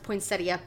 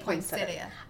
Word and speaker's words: poinsettia: Technically, they're pronounced poinsettia, but I grew up calling them poinsettia.